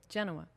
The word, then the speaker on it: Genoa: Genoa.